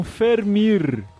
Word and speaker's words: Vermeer: E o V tem som parecido com um F, como no alemão. Então é parecido com Fermir.